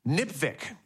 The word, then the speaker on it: NPVIC: A way to pronounce NPVIC, NPVIC, which it rhymes with lipstick.